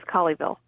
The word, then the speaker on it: Colleyville: Yes, and it is Colleyville.